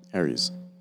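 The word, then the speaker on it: Areas: I don't know if Josh can correct us or not, but it's A-R-E-A-S. We used to pronounce it Aries. – Yeah. It was like Aries or something. I don't know. I probably pronounced it wrong. – I don't know. Josh isn't responding, but So but yeah that one. – You guys are saying it right.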